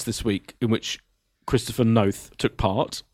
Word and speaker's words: Noth: If we've learned one thing this week, it's how to pronounce Christopher Noth's name. – Okay, so it's Chris Noth rhymes with both.